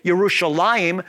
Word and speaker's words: Yerushalayim: the Yah sound became a J sound, as I indicated, is in the case with Yerushalayim, which is the correct way to say Jerusalem, versus Jerusalem.